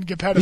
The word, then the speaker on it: Geppetto: I thought it's a soft G. It's not Geppetto. – Yeah, it's Geppetto.